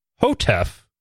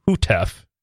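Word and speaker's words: Houteff: So he wrote, it is pronounced Victor Hotef, not Hutef, error number one.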